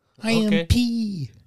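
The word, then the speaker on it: Pei: That's how you say, or that's how it's spelled. See that right there? P.E.I. P. I am P.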